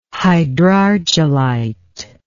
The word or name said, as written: hydrargillite